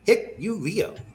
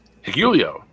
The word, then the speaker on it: Hikuleo: All right, this one you can't blame me for not knowing how to pronounce, because it's spelled H-I-K-U-L-E-O. Hikuleo. – Hikuleo. – Hikuleo. Yeah, you said it. Okay, we'll go with what you said.